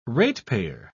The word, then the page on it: ratepayer: British English